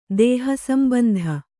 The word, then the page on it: ದೇಹಸಂಬಂಧ: dēha sambandha